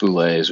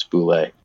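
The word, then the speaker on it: bulette: there is a pronunciation guide in there in parentheses, and it's very clear that it's Boulet.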